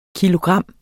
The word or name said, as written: kilogram